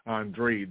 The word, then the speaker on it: Andrade: There was a match, boxing, between David Benavidez and Demetrius Boo Boo Andrade, or Andre, the way that he pronounces it.